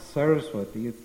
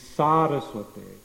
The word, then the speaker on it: Saraswati: So in Prabhupada's pranam mantra, it's not Saraswati, we're not offering obeisances to Bhakti Siddhanta Saraswati, it's Saraswate. For their tooth, so the first thing is that the first A is a long A.